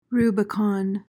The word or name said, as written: Rubicon